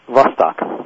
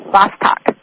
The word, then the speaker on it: Vostok: Lake, and I may pronounce it incorrectly, Lake Vostok? – Vostok. – Vostok.